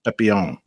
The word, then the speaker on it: Papillion: Old Papillion, Nebraska. Papillion. I don't know if that's really how you say it. Papillion. – Papillion. – Papillon. Yeah. Papillon. Le Papillon. The butterfly. – Well, I mean, I was just saying it like all the other Nebraskans were, so maybe I was saying it wrong.